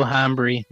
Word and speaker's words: hambre: Mucho hambre. – Mucho hambre. – I'm very hungry. That's very hungry. – Thank you. The H is silent. Come on. – Hambre.